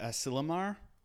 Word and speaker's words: Asilomar: And I'm not sure how to pronounce it, but Asilomar State Park? – Oh, yes.